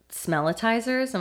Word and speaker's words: smellitizers: How about the smellitizers? Am I saying that correctly? – I think that's the right way to say it, yeah.